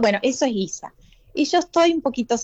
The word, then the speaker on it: Giza: Giza, como Giza. Si fuera, en vez de, como si le pusiéramos una U y una I, como de guiso, pero Giza.